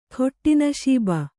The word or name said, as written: ಖೊಟ್ಟಿನಶೀಬ